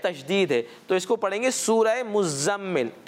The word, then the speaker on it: मुज़म्मिल: देखा गया है कि इसकी लोग इसकी प्रोनाउंसिएशन गलत करते हैं। मीम जो ज़ा पर तशदीद है इसको नहीं पढ़ते, तो इसको यूं पढ़ेंगे सूरह मुज़म्मिल।